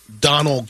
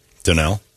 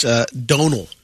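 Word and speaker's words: Domhnall: I would say in the past it would be Donald Gleason. – Donnell? – It's Donal. – Oh, it's Donal. – Yeah. – You sure? – Yeah. – Hmm. Dominal? – And I would say... – hold on. – It's pronounced Donal.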